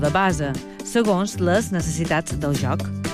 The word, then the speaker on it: base: El base o la base, pronunciat amb essa sonora i a neutre.